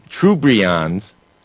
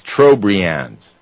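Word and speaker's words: Trobriand: Actually, Nimoy pronounces it Trubrian. It's Trobrians. And it's a chain of at least 23 islands.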